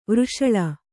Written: ವೃಷಲ

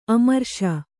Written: ಅಮರೇಶ

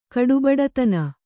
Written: ಕಡುಬಡತನ

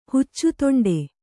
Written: ಹುಚ್ಚುತೊಂಡೆ

